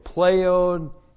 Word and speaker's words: pleonekteo: Plus the aorist active indicative of pleonekteo. Pleonekteo. Looks like that in the Greek, P-L-E-O-N-E-K-T-E, long O, pleonekteo.